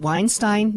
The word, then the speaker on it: Weinstein: I mean, I realize now, you look at Harvey, is it Weinstein? Now, we've talked about his name. Did I say that right? – Well, technically, yes, you did. Media probably won't agree, because they keep on, you know, messing it up, But and for one, my experiences in Germany and sitting in high school German class, it's Weinstein.